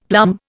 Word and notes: plum: Indian

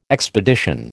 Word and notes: expedition: American English

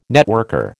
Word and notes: networker: American English

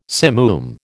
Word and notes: simoom: American English

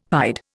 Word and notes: pied: Indian

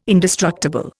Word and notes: indestructible: Indian

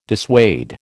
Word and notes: dissuade: American English

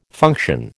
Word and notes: function: American English

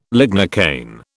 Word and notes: lignocaine: British English

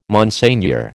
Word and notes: Monseigneur: American English

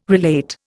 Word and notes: relate: Indian

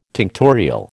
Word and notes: tinctorial: American English

